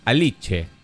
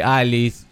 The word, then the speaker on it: Alice: Eh, después, Mordi, que teníamos una duda, eh, dice em, que se pronuncia Aliche. Que decíamos, viste, Alice. – Alice Aliche. – Aliche. Porque es una palabra grave.